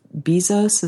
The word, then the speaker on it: Bezos: I hear people complaining about them, you know, being a sprawling giant, but I admire Jeff Bezos, is that uh-huh.